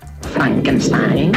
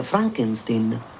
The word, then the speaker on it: Frankenstein: Frankenstein? Frankenstein? Vuol prendermi in giro? – No, si pronuncia Frankenstein.